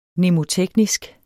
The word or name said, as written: mnemoteknisk